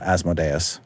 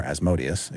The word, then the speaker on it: Asmodeus: Each one has an archdevil sort of lording over it with the head honcho, Asmodeus, or Asmodeus, if you prefer. – Both pronunciations are acceptable.